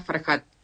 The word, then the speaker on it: Farhat: PS, que es psicóloga, Valeria Farhat. – Con H. – Claro, con H, pero se pronuncia con J.